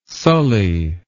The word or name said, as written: sully